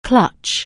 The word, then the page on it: clutch: anglais